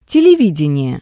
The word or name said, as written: televidenie